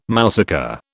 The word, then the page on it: mousaka: British English